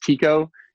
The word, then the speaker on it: Tycho: Um, yeah, I was just reading a lot about astronomy and and uh, Tycho It's actually pronounced Tico is how the guy pronounced it, but I just pronounce it Tycho because I'm American.